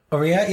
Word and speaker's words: Aurier: I can never remember how you pronounce his name. – And you're asking me? – Oh, yeah. – Aurier? – Aurier, yeah.